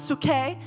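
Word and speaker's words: suke (ψυχή): So soul, which is actually pronounced, it looks like psyche, but it's suke, the seat of, yeah, they roll their tongue when they pronounce, I can't do that, I'm sorry, I love y'all, but the seat of the feelings, desires, affections, aversions, okay?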